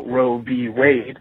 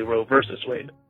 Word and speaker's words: Roe v. Wade: I just wanted to point out that in a court of law, when you're talking about the case, they do initialize the V, but they don't pronounce it versus. So Roe v. Wade is, you know, what we would say typically, not exactly Roe versus Wade.